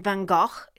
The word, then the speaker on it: van Gogh: Vincent van Gogh is the actual pronunciation.